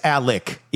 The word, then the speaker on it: Alec: But it's Alec, even though it's spelled Alex.